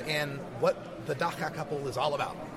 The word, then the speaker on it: Dagga: I am here with the Dagga couple. You said it correctly.